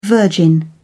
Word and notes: the Virgin: British English

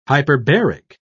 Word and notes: hyperbaric: British English

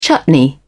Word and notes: chutney: British English

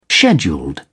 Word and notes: scheduled: British English

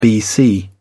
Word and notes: bc: British English

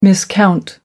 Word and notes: miscount: American English

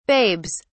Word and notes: babes: British English

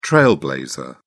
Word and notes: trailblazer: British English

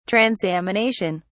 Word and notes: transamination: British English

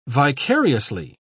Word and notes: vicariously: British English

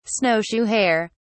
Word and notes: snowshoe hare: British English

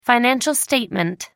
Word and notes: financial statement: American English

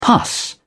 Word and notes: pus: British English

